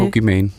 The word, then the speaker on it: Pokimane: Ninja, øh, Pokimane, eller Pokimane, jeg ved ikke helt, hvordan man udtaler... – Øh, Pokimane.